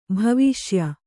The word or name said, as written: ಭವಿಷ್ಯ